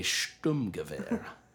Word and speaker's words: Sturmgewehr: I believe the correct pronunciation of it is a Sturmgewehr.